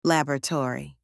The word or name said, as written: laboratory